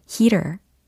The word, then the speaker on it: heater: 근데 난방기 히터의 heater의 경우는 어, 철자는 h e a t e r이고 heater, heater, heater 이렇게 모음 부분에 뭔가 발음이 훨씬 더 열려 있다라고 상상하시면서 발음해 주시면 좋겠습니다.